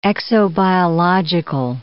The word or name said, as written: exobiological